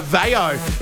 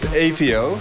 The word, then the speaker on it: AVAO: They're both from the Netherlands, and their names are AVAO. Is that how you say it? – No. – No. – It's AVAO.